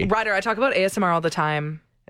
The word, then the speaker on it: ASMR: Can you tell me again what the hype is all about with Asmir? – Ryder. – What? – That's not how you say it. It's ASMR, which stands for Autonomous Sensory Meridian Response, which is that feeling, static-like, tingling, goosebump sensation response to some sort of audio or visual stimulation.